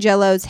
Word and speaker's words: Jellot: Oh, so it's spelled J-E-L-L-O-T, and I believe it's Jello.